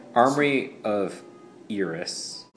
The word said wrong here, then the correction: Iroas: Is it how you say it? Iris? – Iris. Iroas. – Iroas.